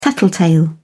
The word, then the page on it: tattletale: British English